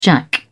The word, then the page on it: jack: British English